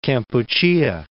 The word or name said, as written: Kampuchea